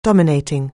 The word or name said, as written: dominating